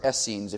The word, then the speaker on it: Essenes: This third group comes into play, known as the Essenes, or Essenes if you want to pronounce it that way.